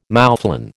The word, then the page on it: moufflon: American English